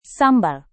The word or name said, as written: sambal